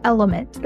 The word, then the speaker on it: LMNT: This podcast is brought to you by Element, that's spelled L-M-N-T, which is a company that I had the chance to invest in and have loved since day one.